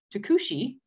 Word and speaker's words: Tucuxi: And the Tucuxi, right? That's how it's said, mm-hmm. right? It's a T-U-C-U-X-I.